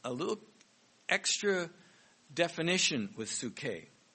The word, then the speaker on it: psuche: And that Greek word is psuche. I'll spell it here. The Greek word is P-S-U-C-H-E, psuche.